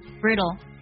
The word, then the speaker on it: brittle: 有个单词呢非常的好玩，它读作 brittle，特别要注意一下词尾 P-P-L-E，在美音当中它会读作 brittle。